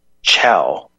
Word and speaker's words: Chao: And Mer Bailey mentioned in the chat that Mitch McConnell's chick is pronounced chow. C-H-A-O is pronounced chow.